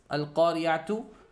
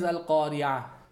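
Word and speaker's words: القارعة: When you stop on it, you omit that. Now, when you omit it and ta marbuta comes around, it is actually not pronounced as a ta. It's pronounced as a ha. That's why it is Al-Qari'ah.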